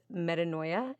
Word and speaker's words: Metanoia: Metanoia.